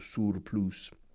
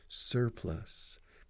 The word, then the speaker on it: surplus: Escrito surplus, pronunciado surplus. Como si, señor, yes, sir, y luego plus.